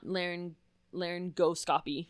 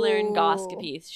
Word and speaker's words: laryngoscopy: I thought there was no, like, I thought you didn't pronounce the G. – No, the G exists. It's just not laryngoscopy, which is what I said. – Oh. – Laryngoscopy.